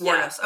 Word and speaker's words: Wuornos: A quick plot summary is that this is the story of Eileen Wuornos. I believe I'm saying that correctly. – I think it's just two syllables. – I think it's Wuornos. – Wuornos, okay.